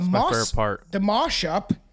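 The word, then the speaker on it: Maushop: It began with the mosh-up. That's how it's pronounced, but it looks like how Dead Mouse spells his name.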